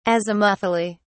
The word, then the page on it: azimuthally: British English